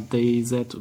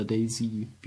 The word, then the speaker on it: DayZ: und zwar DayZ oder Daisy, wie man es auch aussprechen möchte,